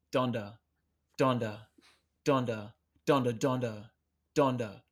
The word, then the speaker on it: Donda: Donda was the correct pronunciation, though. – I believe so. Yeah, yeah. – It's not Dunda or no, so, something.